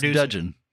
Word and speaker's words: Dudgeon: And Gus Dudgeon. Gus Dudgeon. I always pronounce it Dungeon, and I apologize.